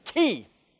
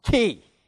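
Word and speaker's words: key (כי): Now you have the key clause, and I use the word key very deliberately because the Hebrew word here is key. That's the Hebrew word. I've just pronounced it. K-I with a long, key.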